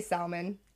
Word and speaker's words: salmon: Because you do say salmon. – Yeah, and I'm literally, the next time I see a sal, I'm going to call him Say. What? Because that makes no fucking sense to me. – It doesn't, but the English language doesn't make sense. – Who made that rule that L next to M is going to be E?